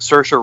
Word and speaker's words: Saoirse: And I like Saoirse, I think that's how you say it, Saoirse Ronan.